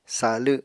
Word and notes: sa7lw7: Apatani

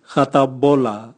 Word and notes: gatabola: Raga

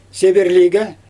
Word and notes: seberlijge: Xyzyl

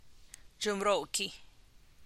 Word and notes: dxumm gie: Teotitlán del Valle Zapotec

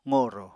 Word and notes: qorò: apatani

